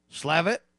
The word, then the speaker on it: Slavitt: White House coronavirus advisor, Andy, how do you say this guy's name? Slavin? Slavitt suggested a pandemic response press briefing in March.